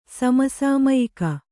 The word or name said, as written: ಸಮಸಾಮಯಿಕ